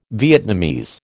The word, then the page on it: Vietnamese: American English